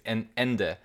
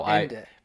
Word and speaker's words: Ende: And actually, on that name of that place, Ende is how Ende. – Because I'm thinking of it as the German word for end. So I guess, or at least I pronounce it that way.